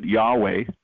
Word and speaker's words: Yahweh: Instead of YHWH, we put in the vowels in it and pronounce it Yahweh.